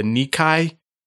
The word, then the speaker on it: Nikkei: And this index is, I might say this wrong, the Nikkei 225 stock average.